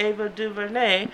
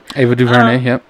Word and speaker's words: Ava DuVernay: I would think that you would talk about it in some capacity, like, hey, yo, did you know this series that Ava DuVernay, mm-hmm. look at me pronounce the words correctly. – Ava DuVernay, directed.